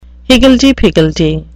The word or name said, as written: higgledy-piggledy